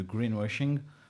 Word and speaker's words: greenwashing: où on va vous parler surtout de greenwashing. Je l'ai bien prononcé là ? – Greenwashing. – Mm-hmm. C'est mieux.